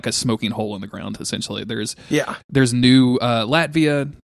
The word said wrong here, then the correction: Octavia: Wait, am I saying that right? – Octavia. – There's Octavia.